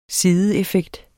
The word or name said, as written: SIDEEFFEKTERNES